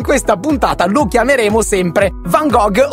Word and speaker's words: Van Gogh: Premettiamo che ci siamo informati sulla corretta pronuncia del cognome e abbiamo scoperto che si pronuncia Van Gogh, visto che la G in olandese è tipo una H.